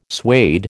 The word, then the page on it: suede: American English